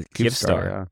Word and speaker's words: Kyivstar: It's a Kyivstar. Kyivstar. It's a Kyivstar? Am I saying that right? – I think so, yeah.